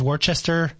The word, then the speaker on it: Worcester: So number 1 is Worcester, Massachusetts.